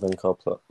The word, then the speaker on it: Unkar Plutt: I'm also going with Unkar Plutt. – 4-4, that's the correct answer. And we do accept that pronunciation given by Lord of Shadows. I said it the exact same way.